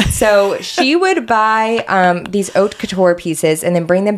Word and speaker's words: haute couture: Also, I think it's pronounced haute couture instead of hot couture. – You are so right. Haute couture.